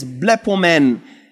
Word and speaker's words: blepomen: The word there is blepomen in the Greek. We see Jesus. Blepomen, to observe, to be watchful of, to discover by use. Blepomen. We blepomen Jesus, spelled how it's pronounced, B-L-E-P-O-M-E-N. We observe Jesus.